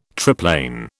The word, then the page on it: triplane: British English